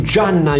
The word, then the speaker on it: jnana: Sono il karma yoga, il jnana yoga, non Gianna il nome, eh, però si pronuncia simile,